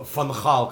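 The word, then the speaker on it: Van Gaal: Фан Хал, как его правильно говорить, да.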